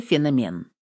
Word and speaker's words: феномен: После записи интервью она пришла к Фаине Георгиевне и сказала: «Все хорошо, но в одном месте нужно переписать слово «феномен». Я проверила, современное звучание должно быть с ударением в середине слова.